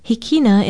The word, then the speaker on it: hikina: Hikina is spelled H-I-K-I-N-A, and it means east.